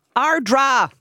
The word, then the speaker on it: Ardara: Ardra. – No way. – Yeah. – Okay. – Everybody in the world mispronounces Ardra, but it is spelt Ardara. Yeah.